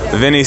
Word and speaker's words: Vinnie: My name is Vinny Cent. V-I-N-N-I-E, Cent. C-E-N-T.